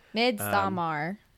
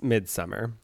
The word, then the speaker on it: Midsommar: But it is spelled Midsommar. – Midsommar. – Yeah, but it's Midsummer. – You can say either or. Ari Aster himself said so.